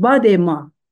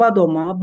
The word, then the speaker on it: badema: Onun bir başka şarkısı Badema Badema biz ben Zazaki lehçesini e, konuşabiliyorum Kürtçenin. Badema biz, bizde de yani aynı anlamda Farsçada bizden sonra bizde de badoma, badema şeklinde telaffuz ediliyor.